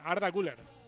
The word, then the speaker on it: Arda Güler: Sí, pero yo creo que ha exagerado un poquito la parte de la I, porque yo lo he puesto en Forbo, expertos en pronunciación hace un ratito para constatar la pronunciación otomana, y es de forma más natural, Arda Güler.